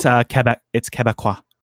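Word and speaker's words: Québécois: It's Quebecois.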